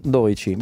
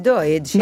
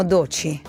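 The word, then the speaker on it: Doechii: Per ricordarmi come si pronuncia, io penso al numero Doici, visto che prima parlavamo di 13. – Il 12, Doici. – Io la chiamo, però la chiamo Doci.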